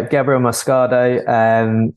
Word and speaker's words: Gabriel Moscardo: I think it's pronounced Gabriel Moscardo or something like that, Corinthians. – Yeah, Gabriel Moscardo.